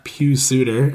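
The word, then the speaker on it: Pius Suter: Anyone who's playing with Kane or the newly ascendant Pugh Suter, as we've decided his name is pronounced, yeah, that could be worth a look.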